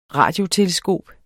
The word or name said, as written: radioteleskop